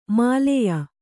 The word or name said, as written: ಮೇಲಾಯ